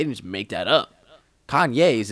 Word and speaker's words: Kanye: But it makes even more sense that when you understand that the emphasis his parents put on his name is literally Kanye. It's not Kanye. It's Kanye. That's how you pronounce his name.